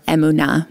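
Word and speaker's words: emunah: Emuna, or emuna, more properly pronounced in Hebrew.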